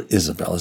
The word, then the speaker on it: Isabel: Now, remember that Jezebel, or Isabel, as it would have been said, well, that's the way it is in the Book of Mormon, and that's how it would have been pronounced, right? It didn't have a J sound like that.